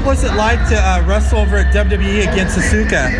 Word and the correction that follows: Asuka: Against Asuka? – Asuka, sorry. – She might kick your head off too if she knew you pronounced her name like that.